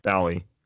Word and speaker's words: Bally: Oh, Valley or Bally? – Bally. B-A-L-L-Y. – Huh. – I'm pretty sure Bally is how you pronounce it. I could be wrong.